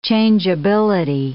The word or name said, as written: changeability